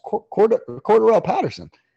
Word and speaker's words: Cordarrelle Patterson: Cordarrelle Patterson. Shit, you said his name right for the first time ever.